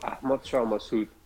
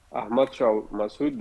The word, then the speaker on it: Ahmad Shah Massoud: And isn't it Ahmed Sarah Massad? Is that the war hero? Did I say that right? – That was Ahmed Shah Massoud. Ahmad Shah Massoud that killed by Taliban side bomber after 9/11.